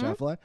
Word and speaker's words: Schlafly: So, you know, in my mind, there was an anti-feminism of, say, the, I don't know, 60s and 70s, like Phyllis Schlafly, is that how you say it? Mm-hmm. Schlafly, who fought against the Equal Rights Amendment for women.